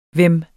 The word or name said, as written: hvem